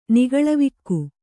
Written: ನಿಗಳವಿಕ್ಕು

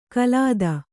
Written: ಕಲಾದ

